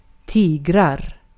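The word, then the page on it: tiger: Swedish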